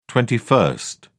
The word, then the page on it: twenty-first: British English